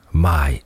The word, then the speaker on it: Maj: Well, we just entered into the month of May, and if you're learning Polish, it's good to know that the name of the month in Polish is not that far off from English, Maj, spelled M-A-J, pronounced Maj.